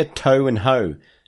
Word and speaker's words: Toho: Next question comes from Andy, who writes, Dear Toe and Ho, that's how you pronounce Toho.